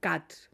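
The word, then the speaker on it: khat: αλλά αυτοί λένε ότι δεν είναι ναρκωτικό αυτό το χάτι, cut προφέρεται, το οποίο το έχουν και στην Αιθιοπία, γενικά στην περιοχή, αλλά εκεί είναι συνήθεια πολύ σημαντική.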